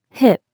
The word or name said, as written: hip